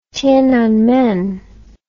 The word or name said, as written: Tienanmen